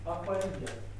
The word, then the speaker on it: апория: Ударение на предпоследнее слово «и» – апория.